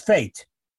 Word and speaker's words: fete: How do you say the word garden? – Fate. – It's fate. – Yeah, obviously, F-E-T-E, fate, it's obvious. – We couldn't go feet. Feet? – I mean, obviously, I would say feet.